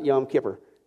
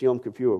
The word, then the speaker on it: Yom Kippur: Now, again, for those of you in the South, it's not pronounced Yom Kippur. Okay, I know you want to say that. I used to say that all the time, personally. But it's not Yom Kippur. It's Yom Kippur.